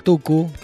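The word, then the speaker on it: Mtukudzi: se llama Oliver Tuku, el tucumano no, Tuku, porque se llama Tukutsi, se escribe con MT, Tukutsi, es el Tuku,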